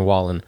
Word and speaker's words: Wallen: Wallen, yeah. – Morgan Wallen thing. – Some people say Waylon because it's like Waylon. It looks like Waylon Jennings' name, but Morgan Wallen.